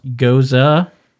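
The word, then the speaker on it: Gose: A goza, pronounced goza, is a historically German refreshing wheat beer that is only slightly tart.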